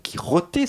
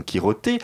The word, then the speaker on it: Quijote: Le festival a son site, festival donc Quiroté, ça s'écrit en espagnol, Q-U-I-J-O T E c'est comme ça que vous le retrouverez sur Internet.